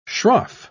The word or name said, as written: shroff